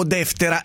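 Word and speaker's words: Deftera (Δευτέρα): Alessandra, l'accento sulla E prima, deftera, o sulla E seconda, deftera, o sulla A, deftera, alla francese.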